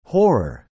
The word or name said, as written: horror